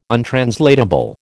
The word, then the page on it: untranslatable: American English